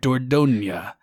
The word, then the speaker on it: Dordogne: And then I also need to shout out Dordogne. That's how the French say it. – Yes. I looked up a pronunciation guide when I did my video on it. Dordogne.